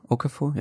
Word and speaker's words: Okafor: Okafor? – Ja.